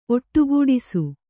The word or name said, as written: ಒಡಗೂಡಿಸು